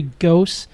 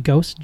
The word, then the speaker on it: Gose: It's a cranberry ghost. Did I say that right? Ghost. G-O-S-E. – Yeah.